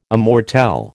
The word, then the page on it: immortelle: American English